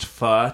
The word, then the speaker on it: pho: Listen, I know we like to joke around a lot, but it is pronounced pho, technically.